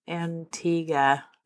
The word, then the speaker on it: Antigua: Antigua.